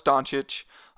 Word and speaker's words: Doncic: Is that how you say it? Doncic. – Yep. – The C's are rough for me. I know it's Doncic.